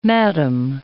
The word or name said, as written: madam